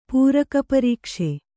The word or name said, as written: ಪೂರಕಪರೀಕ್ಷೆ